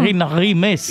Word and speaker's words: Irina Rimes: Irina Rime, sunt direct la DGFM în ajunul uh, Crăciunului. – Pronunță-mă corect, măi Bogdane, mă. – Cum? Irina Rimes.